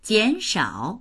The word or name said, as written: jian3shao3